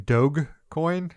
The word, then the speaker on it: Dogecoin: Are you saying Dogecoin? Are you using a hard G? – Yes, I'm saying Dogecoin.